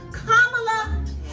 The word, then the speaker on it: Kamala: How dope is it to have the first African-American female nominee of a major party, Miss Kamala, say her name correctly, please, Kamala Harris.